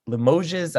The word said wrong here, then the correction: Limoges: I don't know. – Limoges. – See, I knew I was going to pronounce the French wrong.